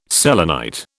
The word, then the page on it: selenite: British English